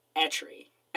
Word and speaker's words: Eitri: Um, Etri is E I T R I." and I'm really just shooting in the dark with these definite with the pronunciations. Uh, Brock is Brock is definitely Brock. It's spelled weird, but it's definitely Brock. Et, it's either Etri or Atri or Itri. I'm going with Etri.